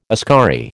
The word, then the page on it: askari: American English